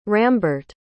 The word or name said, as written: Rambert